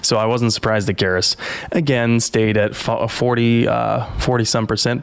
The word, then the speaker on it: Garrus: Garrus.